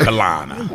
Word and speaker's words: Carolina: Greenville, South Carolina. Oh, let me say it how we say it. I'm trying to be proper right now. Greenville, South Carolina. We take the R-O out. Greenville, South Carolina. – How do you spell that? – Right, C-A-L-I-N-A.